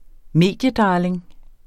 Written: mediedarling

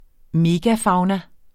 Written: megafauna

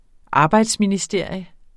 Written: arbejdsministerium

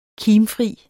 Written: kimfri

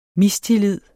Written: mistillid

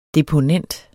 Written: deponent

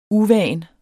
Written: uvant

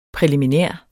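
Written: PRÆLIMINÆRT

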